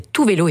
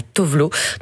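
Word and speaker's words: Tove Lo: C'est Sarah Hendrix qui m'a dit qu'en suédois, on disait Tout Vélo et pas Tovlo. Donc voilà, maintenant, je le prononce bien.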